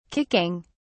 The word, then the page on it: kicking: British English